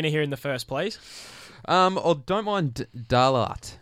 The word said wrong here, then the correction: Dalalat: or how, how do you pronounce that one? Dalalat. Dalalat. Yeah.